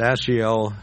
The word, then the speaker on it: Asahel: Now, I believe it's Asahel is the way it's pronounced, was fleet of foot as a wild gazelle.